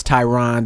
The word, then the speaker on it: Tyron: Like, my name is Tyron, and a lot of people pronounce it Tyrone.